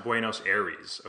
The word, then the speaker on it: Buenos Aires: My father was born in Buenos Aires, and my cousins were raised in Mexico. – It's pronounced Buenos Aires.